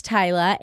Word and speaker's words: Tyla: My name is Tyla.